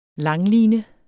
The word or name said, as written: LANGLINE